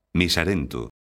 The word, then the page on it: miserento: galega